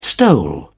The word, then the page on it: stole: British English